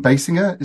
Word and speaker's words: Basinger: You know, apparently Slash comes in and there is Kim Basinger. Is that how you pronounce her surname? – Yes, yes.